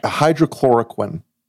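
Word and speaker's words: hydroxychloroquine: Hydro, you know I can't even pronounce it. – Hydrochloroquine. Right? – Hydrochloroquine, yes.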